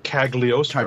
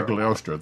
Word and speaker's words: Cagliostro: They pronounced it like it's written Cagliostro. – Cagliostro, thank you.